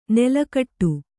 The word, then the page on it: ನಾಲುಕಟ್ಟು: Kannada